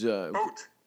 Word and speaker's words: Poot: He was Poot. Mr. Poot. – Is that how you say it? – Yeah, it's double O. P-O-O-T.